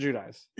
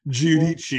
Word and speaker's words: Giudice: Yeah, Teresa Judice. – Yeah. – Okay. – Yeah. I don't, Judice doesn't sound very Italian. I think it's like Giudice, well, but they don't pronounce it that way.